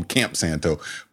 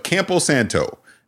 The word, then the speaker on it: Campo Santo: I always want to call him Camp Santo. But Campo Santo, they're the makers of Firewatch.